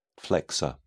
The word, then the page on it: flexor: British English